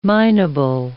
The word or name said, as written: minable